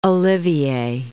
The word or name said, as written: Olivier